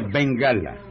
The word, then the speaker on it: Bengala: Un tigre de Bengalia. – En primer lugar, no se dice Bengalia. – ¿Y cómo se dice? – Se dice Bengala.